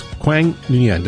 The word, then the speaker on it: Quang Nguyen: And with me today from Christ Cathedral Academy is Quang Nguyen.